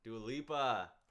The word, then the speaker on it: Dua Lipa: It's like some Dua Lipa. – Dua Lipa? – Dua Lipa.